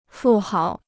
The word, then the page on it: 富豪: fù háo